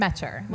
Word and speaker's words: Metcher: Metcher. – Like Metcher. Rhymes with catcher.